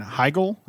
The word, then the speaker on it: Heigl: Yeah, Heigl. She's not German. – It's H-E-I-G-L. – Heigl. There's no EL at the end. It's an IL or just a GL.